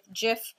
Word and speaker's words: Jif: but there is a thread right now with Jif, the peanut butter company, and Donnie. – What are they talking about? – I can't remember, but it was some kind of validation that it is pronounced Jif.